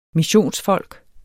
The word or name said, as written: missionsfolk